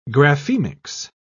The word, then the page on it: graphemics: British English